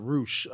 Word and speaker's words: Rush: Like, I know, obviously, Roosh, or, you know, it's spelled Rush, but they call it Roosh.